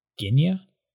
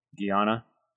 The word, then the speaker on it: Guyana: Guinea? – Guyana.